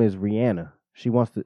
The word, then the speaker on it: Rihanna: Yeah, she's letting niggas know that how to pronounce her name correctly. Mm-hmm. It's Rihanna.